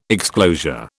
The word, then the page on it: exclosure: British English